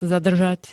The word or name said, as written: zadržať